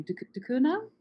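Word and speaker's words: Ducournau: I'm stumbling because I feel like I'm going to mispronounce her second name, de Cunha? – That's how I've heard it pronounced.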